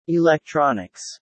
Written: electronics

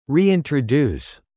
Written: reintroduce

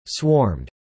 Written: swarmed

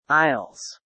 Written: Isles

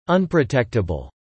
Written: unprotectable

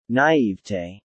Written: naivete

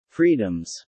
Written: freedoms

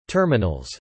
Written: terminals

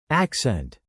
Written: accent

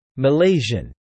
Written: Malaysian

